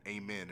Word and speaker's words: Amen: But even without being blessed, they can get an amen and amen Thompson. – Oh, yes, sir. – Is his name not pronounced Amen? – It is.